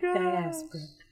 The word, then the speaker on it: diaspora: Black diaspora. Let me say it again so he can edit it. Black dysphoria. – How do you pronounce it? – Diaspora. – A diaspora. Black diaspora.